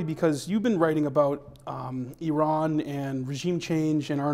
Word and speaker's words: Iran: So I want to start with Iran or Iran or however the, how do you pronounce it? Iran? – Iran would be just as well.